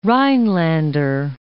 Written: Rhinelander

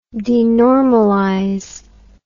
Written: denormalize

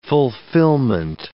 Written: fulfilment

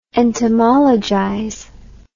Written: entomologize

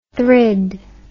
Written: thrid

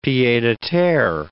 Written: pied-a-terre

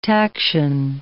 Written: taction

